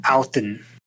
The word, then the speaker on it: Odin: Odin is, the D is pronounced more like a TH, and there's a heavier accent on the end. So it's more like Auten.